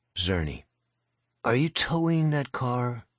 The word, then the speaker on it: Czerny: Thank you for coming in, Mr. Czerny. – Czerny. – You say it, Czerny. C-Z-E-R-N-Y. The C is silent.